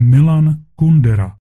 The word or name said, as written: Milan Kundera